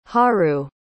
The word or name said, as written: haru